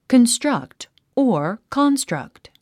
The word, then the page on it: construct: kənstrʌ́kt; カンストラクト